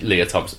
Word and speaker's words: Lea Thompson: Yes, it is Lea Thompson.